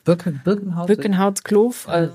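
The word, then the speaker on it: Boekenhoutskloof: Nee, ich habe diese Namen auch alle falsch ausgesprochen, bevor ich in Südafrika war. Und dann wurde ich eines Besseren belehrt von den südafrikanischen Winzern. – Birkenhautskloof.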